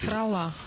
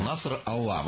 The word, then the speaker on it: Насралла: Кстати, как правильно ударение? Ты же там чего-то учила какие-то... – Насралла. – Да? Да. Насралла. Вот так вот. Вообще нам Гейдар Джамаль сказал, что можно и так, и так.